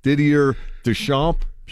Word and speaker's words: Didier Deschamps: Is it Didier Deschamps? – Sure.